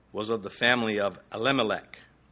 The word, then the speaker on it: Elimelech: I've heard Elimelech's name pronounced three different ways. El-Elimelech is another way, and the third one I don't remember. I don't really care. Elimelech is what you're going to get from me.